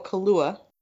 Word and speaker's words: Kaluuya: You got Sacha Baron Cohen, Trial of Chicago 7, Daniel Kaluuya. Is that the right way to pronounce his name? – Yeah.